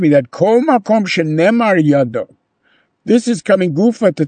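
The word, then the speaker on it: yado (ידו): Now, yud, dalet, vav can be pronounced yado, right? It doesn't necessarily say yadav.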